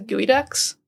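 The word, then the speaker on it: geoduck: No, I had a basketball tournament there one time, and their mascot is the Gooey Ducks, but it's spelled like Geoducks.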